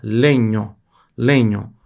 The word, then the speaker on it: legno: Se escreve com GN assim, porque GN italiano tem som de NH. Legno, legno.